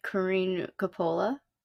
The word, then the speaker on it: Corinne Coppola: Corinne Coppola? – Yes.